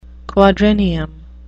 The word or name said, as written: quadrennium